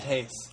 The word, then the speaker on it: Reis: Jim Lee out, Ivan Reis in on Justice League? – We knew he was leaving. Yeah, that's actually pronounced Hayes. Ivan Hayes.